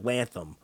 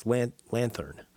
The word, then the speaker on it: lanthorn: And it looked like lantham. It's lanthorn. Lanthorn. Lanthorn. A lantern. But it's spelled L-A-N-T-H-O-R-N. And lantern is spelled L-A-N-T-E-R-N.